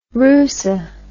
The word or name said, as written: rusa